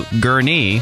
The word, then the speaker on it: Gurnee: You said you needed a ride to Gurnee. That's the way you're supposed to pronounce it.